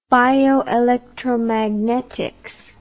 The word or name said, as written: bioelectromagnetics